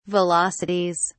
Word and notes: velocities: British English

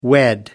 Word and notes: unwed: American English

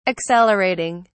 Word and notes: accelerating: British English

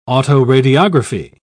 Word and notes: autoradiography: British English

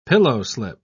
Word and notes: pillowslip: British English

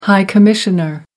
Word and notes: high commissioner: American English

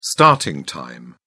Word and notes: starting time: British English

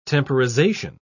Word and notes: temporization: British English

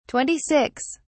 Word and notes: 26: British English

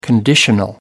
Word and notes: conditional: American English

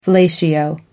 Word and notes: fellatio: American English

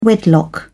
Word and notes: wedlock: British English

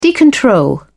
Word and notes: decontrol: British English

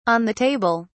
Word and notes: on the table: British English

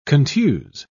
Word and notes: contuse: British English

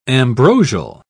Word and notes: ambrosial: British English

